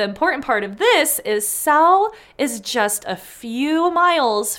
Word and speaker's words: Salle: there are records that this Simon guy held lands in the Salle. That's S-A-L-L-E. I looked it up. It is pronounced Salle.